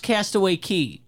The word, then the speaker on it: Castaway Cay: Castaway Cay is written Castaway Cay. It is actually pronounced Castaway Cay. – Mm-hmm. – So you're going to sound like an idiot if you pronounce it the way it is very clearly written.